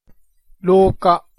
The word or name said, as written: roka